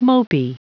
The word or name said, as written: mopey